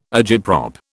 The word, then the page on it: agitprop: British English